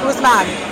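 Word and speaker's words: Ousmane: Is it Usman? – Usman. – Did Adam Silver pronounce it correctly? – He did pronounce it correctly, Usman.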